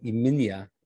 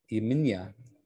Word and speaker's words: Xemxija: I think it's something like Yminya. Yminya. – X-E-M-X-I-J-A.